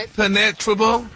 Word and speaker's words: impenetrable: Impenetrable. Impenetrable. – No, your emphasis is on the wrong syllable.